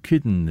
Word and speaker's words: Kidden: Now, Kidden is evidently the pronunciation of the name generally given these days as Cain, but I don't know whether that means his father was called Lenny or whether John Kidden was associated with the Len, perhaps.